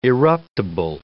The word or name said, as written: eruptible